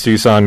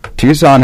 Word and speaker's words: Tucson: One of the other writers in All Sports Tucson wrote a special on that when that happened, so I'll take a look real quick.